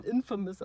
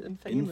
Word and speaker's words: Infamous: ich heiße quasi, wenn dann Infamous und nicht Infamous.